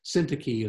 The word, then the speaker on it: Syntyche: Took me a long time to figure out how to pronounce that word, but Syntyche is the right pronunciation.